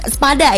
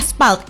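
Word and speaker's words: SPALD: Pagi ini kita mau bahas tentang spalt, bukan spada ya, spalt, S-P-A-L-D-T.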